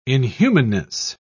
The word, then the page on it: inhumanness: British English